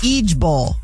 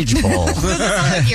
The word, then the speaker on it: IJBOL: So the new LOL is IJBOL, but it's pronounced Ejbol. And I was like, is that just like Jubal, but like in a different... – Ejbol.